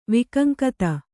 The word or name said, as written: ವೈಕಂಕತ